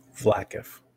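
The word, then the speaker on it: Vlaakith: H Vlakif.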